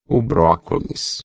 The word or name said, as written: brocolis